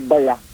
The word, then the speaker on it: baya: Ano man tamang pronunciation doon? Baya o baya? – Pagbigkas ko dyan ay baya.